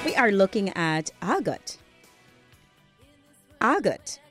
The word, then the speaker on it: argot: It is spelled A-R-G-O-T. You may be wondering why not say argot. But the pronunciation that I'm giving to you is the British pronunciation that we use in standard English in Guyana, as opposed to the American pronunciation. So it is argot, and it is spelled A-R-G-O-T.